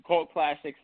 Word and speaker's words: CVLT Classics: Yeah, so um, so yeah, pronounced Cult Classics because uh, I couldn't get the the U on on any of the URLs, okay.